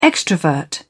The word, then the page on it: extrovert: British English